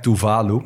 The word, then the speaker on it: Tuvalu: Het is dus wel echt Tuvalu met klemtoon op de 2e lettergreep.